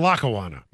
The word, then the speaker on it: Lackawanna: Or Lackawanna. – And you pronounced it correctly.